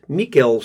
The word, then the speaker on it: Mikkel: He pronounced it Mikkel so it rhymes with veal.